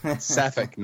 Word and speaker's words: Sapphic: That's Sapphic, not Sapphiric. – Sorry.